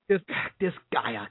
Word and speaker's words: Disgaea: Disgaea. – Did I say it right? Disgaea.